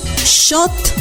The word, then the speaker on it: xot: Para decir comal, decimos shot. Shot se escribe X-O-T.